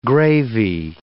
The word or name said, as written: gravy